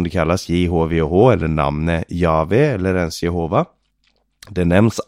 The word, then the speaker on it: Jahve: Och det uttalar man Jahve. Tidigare så trodde man att det skulle uttalas Jehova. Men det är antagligen en missförståelse.